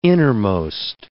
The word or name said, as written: innermost